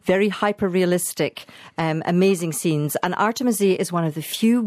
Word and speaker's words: Artemisia: She is actually one of my favourite artists, Artemisia, if I can slightly correct your pronunciation.